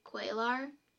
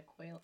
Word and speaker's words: Cuellar: Cuellar. Does that sound like I said it right? – Javier Perez de Cuellar. I gotta look at it. – C-U-E-L-L-A-R. Yeah.